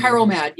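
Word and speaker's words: Chiral Mad: Is it Cheryl Mad? Your anthology that you co-edited? – Oh, Carol Mad.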